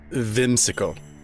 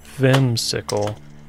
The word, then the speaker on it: Whimsickle: It's Whimsical. – Whimsical? – No. You have to pronounce the V.